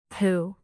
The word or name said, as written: poo